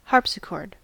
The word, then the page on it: harpsichord: American English